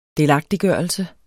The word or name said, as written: delagtiggørelse